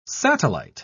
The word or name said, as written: satellite